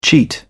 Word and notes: cheat: British English

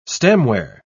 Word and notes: stemware: British English

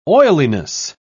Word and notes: oiliness: British English

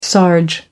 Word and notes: sarge: American English

